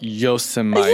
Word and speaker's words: Yosemite: But Yosemite, it's got tons of waterfalls. – You did it again. – Damn it. I really thought I had it. You literally even slowed down and went, Yosemite.